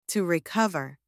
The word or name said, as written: to recover